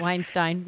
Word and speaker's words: Weinstein: Weinstein, yeah.